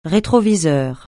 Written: rétroviseur

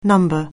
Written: number

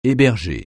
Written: héberger

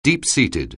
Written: deep-seated

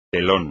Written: telón

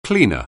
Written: cleaner

